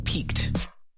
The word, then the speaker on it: piqued: Why is the curiosity of researchers, there I said it right, piqued? How do you spell that word? Piqued, P-I-Q-U-E-D? Like pique your curiosity? – I thought it was P-E-A-K.